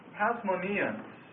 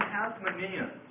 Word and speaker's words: Hasmoneans: And then there's one final name that I'd like to leave you with today, and that's the title Hasmoneans. The term Hasmoneans. And the period of Hasmonean rule. Hasmoneans, that's spelled H-A-S-M-O-N-E-A-N-S.